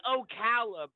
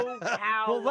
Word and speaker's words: Ocala: How do you say it? It's Ocala, bro. Yeah. Ocala.